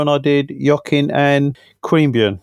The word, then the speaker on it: Queanbeyan: Is that how you pronounce it? – Do you know what? I'm not sure what the correct pronunciation of, as a pretty bogan Aussie, I would probably say Yokine, but that's probably wrong. But you certainly got Queanbeyan correct.